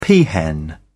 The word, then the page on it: peahen: British English